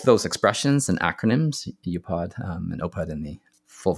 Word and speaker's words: UPOD: It's an acronym spelled UPOD, and I pronounce it UPOD, which stands for under promise and over deliver, right?